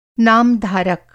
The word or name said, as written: नामधारक